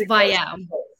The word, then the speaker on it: Vaiao: Yeah, it's Vaiao.